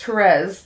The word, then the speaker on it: Therese: Therese? – Thank you. So, or Therese. – Therese, sorry, the way it's pronounced.